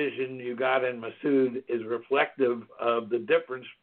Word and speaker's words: Massoud: From what you just said, proper pronunciation is Massoud.